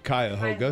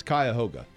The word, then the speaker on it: Cuyahoga: Yeah, now in Ohio, we have Cuyahoga. – It's a river. – All of us from Ohio would know how to pronounce it.